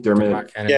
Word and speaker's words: Dermot Kennedy: but have you heard how do you pronounce it? Was it Dermot Kennedy? Dermot Kennedy.